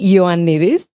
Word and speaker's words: Ioannides: I'm Hélène, Hélène Alexi Ioannides.